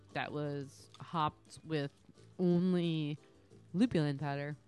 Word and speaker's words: lupulin: Did I say it right? Lupulin? – Lupulin.